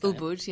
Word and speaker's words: Ubud: Now, what happened while they were in Ubud? Am I saying that right? Ubud? – I think Ubud, yes, Ubud.